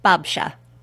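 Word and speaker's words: babcia: Babsha.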